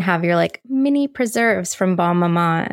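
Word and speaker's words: Bonne Maman: No, I think you got it. I think it's Bon Maman, whatever.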